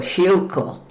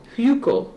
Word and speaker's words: Hukill: Oh, Hukill. – Hukill. – It's H-U-K-I-L-L. – Oh, I see. I spelled it wrong.